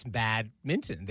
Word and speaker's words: badminton: and she explained to me, Americans say badminton, like it's what you wear when you're going for a snowball fight. – Right, badminton. – But it's badminton. There's an N there before the T.